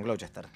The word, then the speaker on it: Gloucester: Ah, perdón la pronunciación, Gloucester.